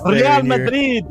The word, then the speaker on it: Real Madrid: I'm about to get on you for your lack of Spanish. – Real Madrid.